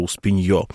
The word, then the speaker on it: Успенье: называется Успенье, ударение на «ё», Успенье, так в народе трансформировалось, и поэтому, конечно, у этого праздника есть очень много всяких народных осмыслений.